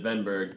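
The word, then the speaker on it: Wennberg: Yeah, and I think he pronounced it Wenberg because it's that whole European WV switch that I never catch on.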